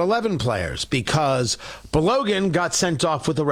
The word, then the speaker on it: Balogun: B-A-L-O-G-U-N. I believe it's pronounced Balogun, and that's the way I'm going to pronounce it.